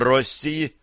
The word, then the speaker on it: России: Император России, ударение на «о», провел серию приемов, бла-бла-бла-бла.